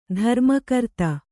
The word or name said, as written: ಧರ್ಮಕರ್ತ